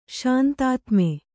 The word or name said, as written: ಶಾಂತಾತ್ಮ